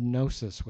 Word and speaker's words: gnosis: This is the word gnosis with the silent G, G-N-O-S-I-S, which means knowledge by experience.